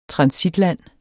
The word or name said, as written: transitland